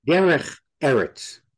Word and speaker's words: Derech Eretz: And with that, tell me if I'm pronouncing this right. We discuss Derech Eretz. – Derech Eretz. – You say it much better than I do.